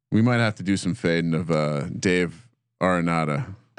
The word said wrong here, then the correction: Dave Aranda: Dave Aranda.